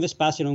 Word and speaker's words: Vespasian: Just type John Vespasian on Google or on any search engine, and you will find my stuff very, very quickly.